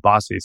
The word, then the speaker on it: Baci: B-A-C-I. And then I Googled, how do you pronounce B-A-C-I? And I got Bossy.